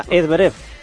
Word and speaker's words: Zverev: Zverev, ¿no? – Y eso que me lo han dicho Víctor Catalina, Zverev.